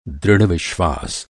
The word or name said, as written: दृढ़